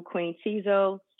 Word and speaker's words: Queen Tizo: If you just Google Queen Tizo, my website will come up. And the name is spelled Q-U-E-E-N-T-I-Z-O.